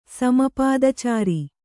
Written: ಸಮಪಾದಚಾರಿ

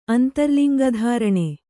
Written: ಅಂತರ್ಲಿಂಗಧಾರಣೆ

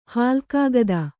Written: ಹಾಲ್ಕಾಗದ

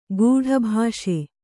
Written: ಕಟ್ಟುಬ್ಬಸ

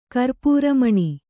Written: ಕರ್ಪೂರಮಣಿ

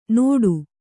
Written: ನೋಟು

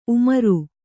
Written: ಉಮ್ರಾ

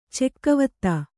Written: ಚೆಕ್ಕೆವತ್ತ

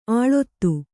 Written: ಅಳೆತ

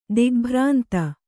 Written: ದಿಗ್ಭ್ರಾಂತಿ

